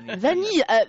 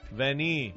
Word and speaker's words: vanille: You know, in France, if you have two L, it's pronounced like a why. – Vanille.